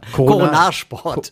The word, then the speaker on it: Coronarsport: Nicht Corona-Sport, das war. – Coronarsport. Coronar, so Corona, spricht man das aus. Ja, bloß nicht. Hörte sich so ähnlich schon an wie Corona. – Ich korrigiere. – Coronarsport, ja.